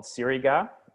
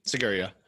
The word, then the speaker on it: Sigiriya: Like we flew into Colombo and then went out to, man, I'm going to butcher this name, but I think it's called Siriga, the like big, huge rock. – Mm-hmm. Sigiriya.